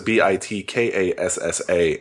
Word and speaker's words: BitKassa: BitCasa is BitKassa, and it's an iPad app.